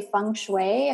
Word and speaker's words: feng shui: And when it comes to it, I'm thinking, I don't even know if I say feng shui, if I'm saying it right.